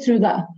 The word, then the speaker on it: Trude: No, my name is Trude. – Trude. – Trude is fine.